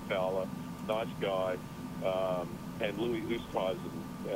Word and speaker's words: Oosthuizen: Oosthuizen said once in an interview that it's supposed to be pronounced Westhuizen.